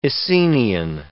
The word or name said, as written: Essenian